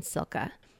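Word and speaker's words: Cilka: Silke, it's C-I-L-K-A apostrophe S.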